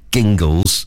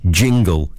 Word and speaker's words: Jingles: He said, no, it's not Gingles. I didn't mean it to be pronounced Gav Gingles. I meant it to be pronounced Jingle, Gav's Jingles, but still spelt with a g, so it sort of alliterates.